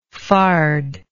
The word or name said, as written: farded